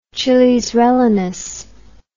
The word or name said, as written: chilis rellenos